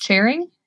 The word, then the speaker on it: Charing: I don't know if I'm saying it right. Charing. – That's right. – Charing. Thank you.